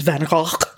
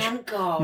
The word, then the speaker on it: Van Gogh: I know Americans say Van Gogh and we tend to say Van Gogh, but Van Gogh. My Dutch friends have informed me that it is pronounced actually Van Gogh.